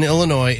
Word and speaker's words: Illinois: Mitchell said, the S in Illinois is silent.